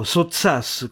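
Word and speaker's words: Sottsass: Quell'uomo fu Ettore Sozzas, per Nanda Ettorino, ma in pubblico Sozzas con l'accento rigorosamente sulla A.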